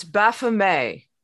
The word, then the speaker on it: Baphomet: It's Baphomet.